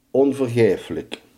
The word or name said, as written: onvergeeflijk